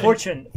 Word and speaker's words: 4chan: 4chan? – Fortune. I think Fortune magazine. – I think it's pronounced 4chan, not Fortune.